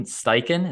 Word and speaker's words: Steichen: Third, offensive coordinator for the Eagles, Shane Steichen.